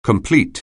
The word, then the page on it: complete: anglais